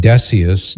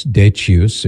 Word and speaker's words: Decius: and Decius, Decius, if you prefer the more Latin pronunciation, he was from the year 201 to the year 251.